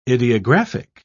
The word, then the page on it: idiographic: British English